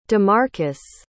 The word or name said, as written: damarcus